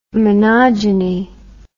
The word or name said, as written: monogeny